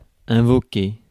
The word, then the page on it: invoquer: [ɛ̃.vɔ.ke]